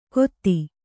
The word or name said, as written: ಗೋತಿ